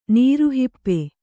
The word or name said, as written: ನೀರುಹಿಪ್ಪೆ